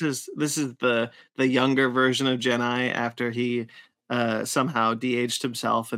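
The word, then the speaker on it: Gennai: Fun little fact, or fun little side note, when I was a kid, I always I always heard it pronounced as Jedi. – Jedi? No, it's Genai. – Yeah, I didn't hear the N. It sounded like a D to me as a kid.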